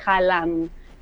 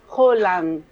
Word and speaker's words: Haaland: Claro, y vos sabés que mi hijo más chico, Teo, me dice, mamá, no se dice Haaland. Y entonces lo puse en el Google, en el traductor, y es Haaland. Así se pronuncia.